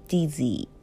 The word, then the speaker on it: dizzy: Z」の発音も「 G」になりやすいので例えば「 busy」とか「 dizzy」とか「 bg」ではなくて「 busy」「 dizzy」こも「図」に小さい「印が入る感じかな。